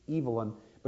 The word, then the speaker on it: Evelyn: It is not uncommon for a man to be named Evelyn, and the male form is pronounced Evelyn, but you can imagine the confusion it causes.